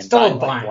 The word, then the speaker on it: Stubblebine: Hello and welcome, Tony Stubblebine. Is that pronounced right? – It's Stubblebine. – Stubblebine. Like right, that was it.